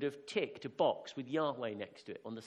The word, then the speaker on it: Yahweh: Well, Yahweh is the probably more correct way to pronounce it.